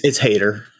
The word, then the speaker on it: Hader: It's Hader.